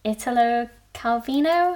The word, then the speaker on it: Italo Calvino: It's Italo Calvino.